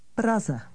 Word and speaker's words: Praze: Notice that the city's name changes slightly in this context. Listen and repeat. – Praze.